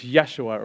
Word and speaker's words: Yeshua: But they didn't have the J, so it's Yeshua, or we pronounce it Joshua.